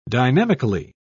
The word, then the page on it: dynamically: British English